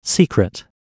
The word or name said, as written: secret